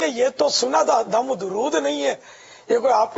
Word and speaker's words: درود: صحیح تلفظ ہے درود, دال پر بھی پیش را پر بھی پیش, درود درود پاک درود شریف.